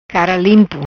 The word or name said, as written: Cara limpo